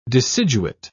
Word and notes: deciduate: British English

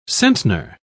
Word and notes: centner: British English

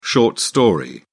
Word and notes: short story: British English

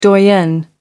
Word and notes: doyenne: American English

